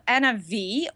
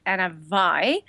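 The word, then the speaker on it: Anna Vie: So it's called Anna V, or maybe you will pronounce it Anna Vi, V-I-E.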